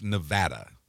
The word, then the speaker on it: Nevada: But it's pronounced Nevada. – Nevada. What did I say? Nevada? – A lot of people say Nevada. – Okay. – And that drives people in Nevada insane.